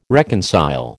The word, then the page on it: reconcile: American English